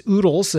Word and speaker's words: Oodles: My artist name is Oodles. That's spelled O-O-D-L-E-S.